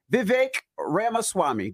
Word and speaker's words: Vivek Ramaswamy: And we got a special guest in the building, Vivek Ramaswamy.